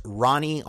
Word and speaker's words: Ronnie: So I'm on Instagram as Ronnie, R-O-N-N-I-E. If you just spell R-O-N-N-I-E and then type G-I, you'll find me because I'm the only one like that anywhere on Instagram.